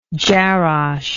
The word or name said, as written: jarash